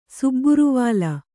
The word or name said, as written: ಸುಬ್ಬುರುವಾಲ